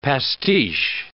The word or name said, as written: pastiche